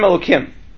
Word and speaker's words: Elokim: The way those actually appear is We pronounce it as Hashem Elokim. Okay? So if you look in a Chumash, how would it actually be written? The Shem Hashem appears twice. Right? So when the Shem Hashem appears twice, the second one is read as Elokim.